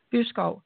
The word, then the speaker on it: Byskov: Elsa Byskov.